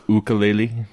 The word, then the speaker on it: ukulele: I'll just stick to the ukulele. – The ukulele? You know, it's no. actually pronounced ukulele.